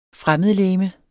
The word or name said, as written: fremmedlegeme